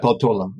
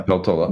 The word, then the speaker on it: Peltola: The winner, Ms. Peltola, I'm not sure how to pronounce that. – Peltola, yeah. – Peltola, yeah.